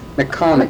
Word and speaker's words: Micahnik: Now, could you pronounce his name for me again? Mikanik? – McConic. – McConic. – M-I-C-A-H-N-I-K.